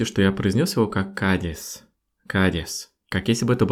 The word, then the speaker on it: Кадис: Но город звучит как Кадис. Кадис. Вот это сочетание «к» и следом за ним идущее «ди» для русской фонетики — это, видимо, что-то невозможное для произношения именно со звуком «и». И поэтому мы трансформируем звук «и» в звук «э».